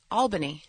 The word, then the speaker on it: Albany: How do you say Albany? – Albany. – Oh, Albany.